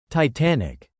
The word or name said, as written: Titanic